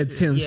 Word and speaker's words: Tems: You say it like Tim's, right? – Yeah, Tim's. Yeah. T-E-M-S.